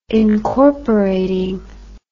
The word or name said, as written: incorporating